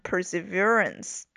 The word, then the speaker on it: perseverance: 注意这个词的发音 perseverance，重音在中间，在这个 verance 这里。